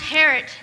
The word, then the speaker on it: Harriet: Harriet rhymes with parrot. – I'm sorry? – It's pronounced Harriet. The I is silent.